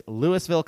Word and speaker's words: Louisville: It's spelled Louisville, but it's Louisville, Colorado, 80027.